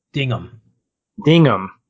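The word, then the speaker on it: Dignam: But even Wahlberg's character, Dingham, is that right? I can't remember how to pronounce it. – Dingham.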